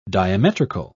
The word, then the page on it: diametrical: British English